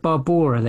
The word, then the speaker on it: Barbora: It's about a Czech painter, and I apologise for the pronunciation, as I always do, but Barbara Kizilkova. Is that right? You're better at these than I am. – It was like kind of Barbora, they say, don't they? – Right, Barbora Kizilkova.